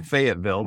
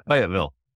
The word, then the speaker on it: Fayetteville: If you're in North Carolina, you don't say Fayetteville. That's Arkansas. You say it, Fayetteville.